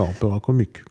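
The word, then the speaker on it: Opéra Comique: Ja, dann gibt es The Sorcerer, uraufgeführt 1877, noch in der Opera Comique. Ich hoffe, das habe ich jetzt richtig ausgesprochen. – Ja, Opera Comique, genau.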